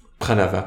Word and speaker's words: pranava: je suis Pravana pranava, pardon, la syllabe aum dans tous les Vedas.